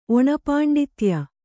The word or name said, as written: ಒಣಪಾಂಡಿತ್ಯ